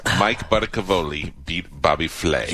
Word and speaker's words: Flay: Speaking of stupid chefs, Mike Butta, the chef from Chain is beat Bobby Flay. – Flay! – Bobby Flay. – Bobby Flay. – Flay. He's not a piece of meat. He's Bobby Flay, not Filet.